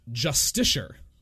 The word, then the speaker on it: Justiciar: Apparently that is a word, and it's pronounced Justiciar.